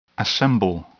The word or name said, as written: assemble